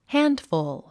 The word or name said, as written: handful